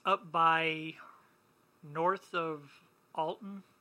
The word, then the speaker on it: Alton: In Alton, the T is silent. It's Alon. Alton.